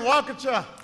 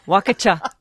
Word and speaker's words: Waukesha: Good to be in Waukesha. – Waukesha. Good to be in Waukesha.